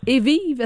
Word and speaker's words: Evive: Et il y a une entreprise de Sherbrooke, on les a vus cette semaine, c'est l'entreprise Evive, c'est comme ça qu'on le prononce, je pense. Hein? – Oui, exactement. Ouais. – OK. – Mais cette entreprise-là, Evive, c'est une entreprise.